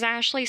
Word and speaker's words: Ashleigh: My name is Ashley Simmons. My name is spelt weird, so A-S-H-L-E-I-G-H, Simmons, S-I-M-M-O-N-S.